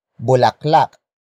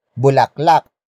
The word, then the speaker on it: bulaklak: It is the focus marker that introduces the main topic of this sentence, and that is bulaklak. Bulaklak. Bulaklak. Bulaklak is spelled as B-U-L-A-K-L-A-K.